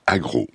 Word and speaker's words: AGR'EAU: le programme AGRO. Ça s'écrit AGR'EAU, jeu de mots. – Comme de l'eau.